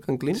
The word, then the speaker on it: Canclini: Sí, estaba escuchando atentamente y quería hacer una pregunta basándome en un autor, Canclini, ¿cierto? ¿Está bien pronunciado, Canclini? – Sí, Néstor García.